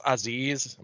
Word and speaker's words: Aziz: But I think being that they put is Aziz, am I pronouncing that right? – You are pronouncing, yes, Commander Aziz, Apollo Crews guy.